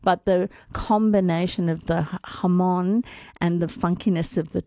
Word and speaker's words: jamón: And it's not jamón. It's spelled jamón with a J, jamón. But it's jamón.